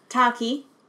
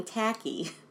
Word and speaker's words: Taki: Taki. – Earlier I was saying Tacky.